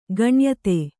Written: ಗಣ್ಯತೆ

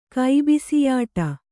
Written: ಕೈಬಿಸಿಯಾಟ

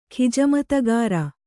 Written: ಖಿಜಮತಗಾರ

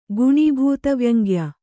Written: ಗುಣೀಭೂತವ್ಯಂಗ್ಯ

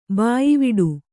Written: ಬಾಯ್ವಿಡು

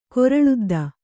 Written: ಕೊರಳುದ್ದ

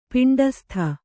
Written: ಪಿಂಡಸ್ಥ

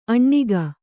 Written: ಅಣ್ಣಿಗ

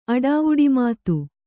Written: ಅಡಾವುಡಿಮಾತು

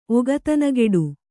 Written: ಒಗತನಗೆಡು

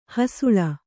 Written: ಹಸಲು

